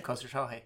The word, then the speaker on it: Khosrowshahi: Yeah, Khosrowshahi.